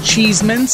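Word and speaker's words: Cheesman: And my last name is Cheeseman, spelled a tad differently, but not enough that I feel like people should be mispronouncing it. There's just no E after the S. But then all of a sudden, it tricks people's brains into thinking my last name is Chessman.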